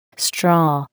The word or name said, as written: straw